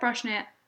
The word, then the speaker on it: Brosnahan: Brosnahan.